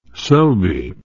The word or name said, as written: selby